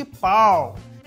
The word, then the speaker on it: Paarl: E um pouquinho mais para cima, a gente vai chegar no distrito de Pau. Se escreve P-A-A-R-L.